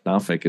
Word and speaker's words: Norfolk: I enjoyed our time down with Andrew Copeland, and you know, we had a really good time down there in I guess it's Norfolk. Norfolk is what I've kind of gathered is how you say it.